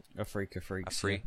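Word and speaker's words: Afreeca Freecs: We had Africa Freaks. Is that how you even pronounce it? – Africa Freaks.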